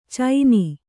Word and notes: ಚೈನಿ: kannada

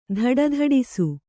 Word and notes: ಧಡಧಡಿಸು: dhaḍa dhaḍisu

